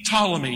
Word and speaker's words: Ptolemy: Cassander, Lysimachus, Seleucus, P. Ptolemy. You don't pronounce it, but it's there written out.